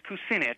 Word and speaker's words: Kucinich: Is that how you pronounce his name, Kucinich? – Yeah, I've heard it as Kucinich.